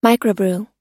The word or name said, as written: microbrew